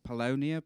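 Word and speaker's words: Paulownia: Palonia? Palowina? – I think it's Palowina. Yeah.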